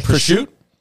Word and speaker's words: prosciutto: It's pronounced prosciutto, isn't it? – Prosciutto.